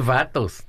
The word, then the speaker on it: vatos: He's not one of Los Vatos anymore. – Right. – Did I say it right? – Yeah, Vatos.